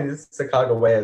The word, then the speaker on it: Sacagawea: Someone told me Sacagawea is the correct way to say it.